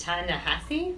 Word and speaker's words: Ta-Nehisi: I learned recently, to my great surprise, that it's pronounced Ta-Nehisi.